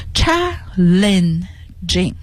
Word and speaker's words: challenging: 好， challenging 这个词呢读音好多人是读得不大清楚的， challenging。好， challenging 这个 L-E-N 中间这个音节 lin 然后 jing。